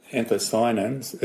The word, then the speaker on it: anthocyanin: Or they'll soon find out how to make anthocyanin.